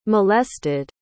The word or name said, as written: molested